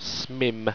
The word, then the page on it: smimm: owstwecian